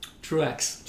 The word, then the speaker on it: Truaxe: Truaxe. – Truaxe. So as it's spelt.